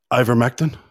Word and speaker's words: Ivermectin: Ivermectin.